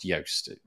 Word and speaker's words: Joost: his name, despite the fact that it's spelled Joost, is pronounced Joost.